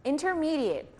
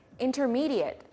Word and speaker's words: intermediate: Intermediary, intermediate 하면 가운데, 중간에 라는 뜻이고요. 동사로 쓰일 때는 intermediate, 중재하다 라는 뜻입니다. 스펠링은 같지만 발음이 다르니 주의해서 들어보세요. Intermediate, intermediate.